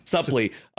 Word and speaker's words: Suplee: And then Ethan Suplee, is that how you pronounce his last name? – Suplee.